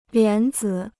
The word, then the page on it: 莲子: lián zǐ